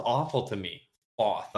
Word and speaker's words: Thoth: So, do you pronounce that Toth? – It's Toth. – Do you say Thoth? – Yeah, that's how the audiobook says it too. Thoth.